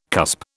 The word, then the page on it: cusp: British English